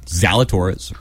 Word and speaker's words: Zalatoris: Zalatoris rhymes with you know? The Taurus.